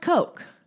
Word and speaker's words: Koch: Now, it's pronounced Koch.